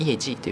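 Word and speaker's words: 业绩: え漢字は全く同じ漢字を書きますけれども、発音はですね、イエジというふうに言います。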